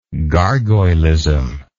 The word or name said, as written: gargoylism